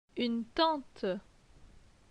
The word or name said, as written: Une tante